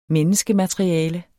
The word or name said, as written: menneskemateriale